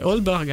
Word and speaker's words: Aalborg: Je sais pas comment en fait, le A-A se prononce O, en danois, c'est comme ça. – Ah bon? – Ce qui fait qu'il ne faut pas dire Vingegaard, comme disent les journalistes, c'est le vélo, ça, hein. Mais il faut dire Vingegaard parce que AA c'est O. Moi, mon fils habite à Aalborg, ça s'écrit AA. – Bah, c'est Aalborg alors.